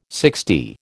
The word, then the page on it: sixty: American English